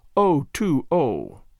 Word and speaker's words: O'Toole: "O'Toole," he told me his name was, pronounced O-2-O.